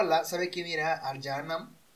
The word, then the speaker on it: Jahannam: Se pronuncia Jahannam. O en árabe, Jahannam.